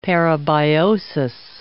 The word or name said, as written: parabiosis